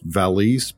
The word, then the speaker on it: valise: He has rheumatism, which today people call arthritis, and he packs his valise, which is a bag, and I'm pretty sure it's pronounced valise, but I've been mispronouncing it to my children for so long, I have to stick with valise.